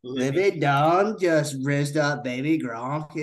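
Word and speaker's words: Livy Dunne: How do you actually say her name? – Livy Dunn. – Okay. – Livy Dunn just rizzed up baby Gronk, and you won't believe what happens next. – Hayden thought it was Dooney.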